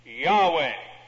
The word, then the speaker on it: Yahweh: Scholars think that the name should be pronounced Yahweh.